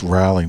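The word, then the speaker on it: Raleigh: Well, you saying it's Riley, North Carolina, which I know that, but it's not spelled like Riley. It's spelled like Ralph almost.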